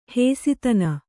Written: ಹಸುತನ

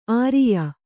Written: ಆರಯ್ಯು

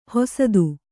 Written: ಹಸಿತ

